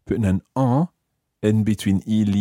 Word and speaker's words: en: And notice what happens with the pronunciation of en there. Normally, en is quite a nasal sound. En. – En. – But when we say il y en a, that you sound the N again.